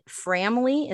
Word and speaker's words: Framly: So like one of the things I was just telling Dallas about, which I think it hasn't launched yet, but is it Framly? Is that how you say it? The app? – Yeah. Yeah.